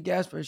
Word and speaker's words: Gaspare: It's like, I always say it's Casper the Ghost with a G, even though it's not it's not spelled that way, but I always say it's Casper the Ghost with a G. And then like my whole entire life, it's been butchered. So like when I was in school, like one time I had a teacher and she was like, Jasper. I'm like, oh no, it's actually Gaspar.